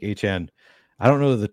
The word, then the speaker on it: Achane: Or Achane, excuse me, Achane. – Achane, that's right. Yeah, we know now. We know now how to say it, so we can.